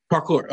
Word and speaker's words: parkour: And, you know, it, it came from, like you said, martial art and gymnastics park hour. Did I say that right? Park hour? Park hour? Parkour? Parkour.